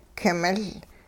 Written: king-miL

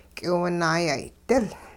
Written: k'iwunaayay'dil

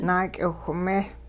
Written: naak'iwhme

